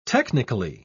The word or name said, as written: technically